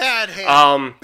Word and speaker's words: Badham: It was directed by John Bedham. Yes, I had to look at my phone for that. John Badham. Bedham? Badham? Bedham? Okay, something. One of those pronunciations. – Badham. Badham. – I like that one.